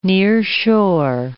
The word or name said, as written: nearshore